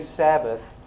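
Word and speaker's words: Shabbat: Today, if you go to Israel, you need to, now the to say Sabbath, it is, they call it, they pronounce it Sabbat.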